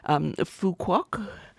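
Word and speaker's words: Phú Quốc: Phu Quoc.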